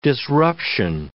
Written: disruption

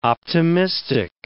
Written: optimistic

